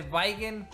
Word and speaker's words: Weigen: Mi apellido es Weigen, se pronuncia supuestamente Weigen, pero pues es muy complicado y todos todos terminan diciéndome como quieran.